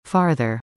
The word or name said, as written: Farther